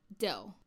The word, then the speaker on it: Do: Instead, he used the names of musical tones beginning with Do. Like, it's pronounced like Do, a female deer.